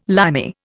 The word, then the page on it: Limey: Indian